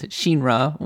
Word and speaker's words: Shinra: Well, it's because it's she, as in she, like in Japanese, Shinra would be how you would pronounce it in the original Japanese.